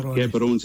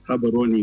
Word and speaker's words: Gaborone: No, it's not Gaborone, it's Gaborone. You know what, Tajweed, if I can put it there.